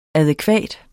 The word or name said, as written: adækvat